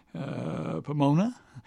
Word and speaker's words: Pomona: That's the way you pronounce it. Pomona, California. At the age, well, goodness, he was born in 1931.